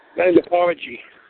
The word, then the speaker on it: gynecology: Gynecology. – gynecology, thank you, and obstetrician. Obstetricate. It's got O-B-S-T-E-T-R-I-C-S.